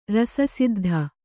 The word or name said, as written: ರಸಸಿದ್ಧಿ